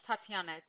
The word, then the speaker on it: Tatiana: Yes, Tatiana. T-A-T-I-A-N-A. – Okay, and it's spelled exactly how it sounds.